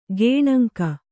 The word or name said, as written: ಗೇಣಂಕ